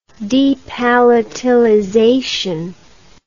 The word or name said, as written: depalatalization